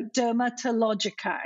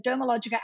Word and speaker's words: Dermalogica: And what we didn't realize is that for whatever reason, people would insert a T in the name. So Dermatologica is a common thing. I probably wish I'd sort of said Dermalogica and the T is silent.